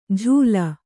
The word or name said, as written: ಝೂಲ